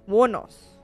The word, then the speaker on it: Wuornos: I'll say Aileen Wuornos. – Wuornos. – Yeah. – Whoa. But where did the O go? Because it's W-U-O-R-N-O-S. – W-U-O, right? The U and the O automatically merge. Wuornos.